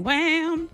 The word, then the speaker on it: Wham: Oh, is that how you say it? Because, I mean, I thought it was Wham. – Oh, it is Wham. – Because it's W-H-A-M.